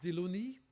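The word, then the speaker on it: Delaunay: So somebody, Russian, published a paper which was translated to French, and therefore his name is pronounced Diluni, but he's Russian.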